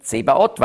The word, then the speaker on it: Zebaoth: Zvaot und dann griechisch ausgesprochen Zebaot, weil das Neue Testament griechisch ist, oder ein hebräisches Wort, griechisch ausgesprochen.